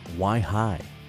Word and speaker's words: Wihi: And we started out with some brand new music from Liz Bills called Why High. You spell that W-I-H-I. Kind of like Wi-Fi, but why? – Yes.